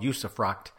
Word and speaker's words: usufruct: It's called usufruct.